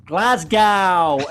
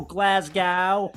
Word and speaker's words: Glasgow: Tell him not to pronounce Glasgow as Glasgow, as every wrestler does. Glasgow.